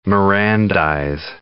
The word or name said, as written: Mirandize